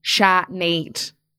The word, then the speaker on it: Shanade: So it's spelled S-H-A-N-A-D-E. Sinead. Like Sinead. Phonetic.